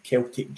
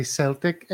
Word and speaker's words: Celtic: And the root word is from the Greek keltoi, K-E-L-T-O-I. I'm sure Ange would approve of those Greek roots. And then there was a time when the C of the word was soft for all uses, the C of the word Celtic. It was always soft. Sometime in the 1700s, the hard C started to become used more widely.